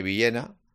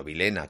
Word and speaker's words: Villena: por supuesto Tony Villena o Tony Vilena, como él se hace llamar, bueno, Vilena, que es la pronunciación correcta, Puado también, el esfuerzo físico de Óscar Gil y poco más, y poco más.